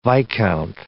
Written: viscount

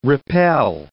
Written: repel

